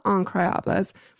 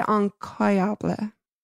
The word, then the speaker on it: incroyable: And when pronouncing the incredible in French, incroyable, they dropped the R entirely as they did in general.